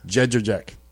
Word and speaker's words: Jędrzejczyk: Jędrzejczyk.